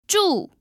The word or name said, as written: zhu4